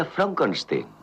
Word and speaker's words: Frankenstein: No, se pronuncia Frankenstein.